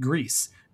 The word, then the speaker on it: Gris: So next month, we are going to be talking about Grease, spelled G-R-I-S. And I've heard it pronounced but like the developers, I'm pretty sure, pronounce it Grease, G-R-I-S.